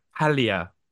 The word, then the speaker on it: Paglia: Um, oh, ah, Paglia. You, don't pronounce the G.